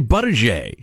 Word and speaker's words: Buttigieg: I've heard so many, I thought I knew. Most recently, I heard Chris Wallace say Buttigieg.